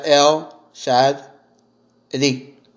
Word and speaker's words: El Shaddai: You pronounce that El Shaddai.